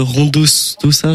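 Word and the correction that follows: Rondodasosa: J'arrive pas à le prononcer. – Rondo da Sosa.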